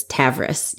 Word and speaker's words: Tavris: And I said that lady's name wrong. It's Tavris, not Tarvis.